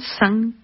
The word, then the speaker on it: Sun: Escuchamos de Johannes Brahms, obertura del Festival Académico por la Orquesta Sinfónica de Malmo con la dirección de Un, un San Kim, que San se escribe como sol en inglés, Sun.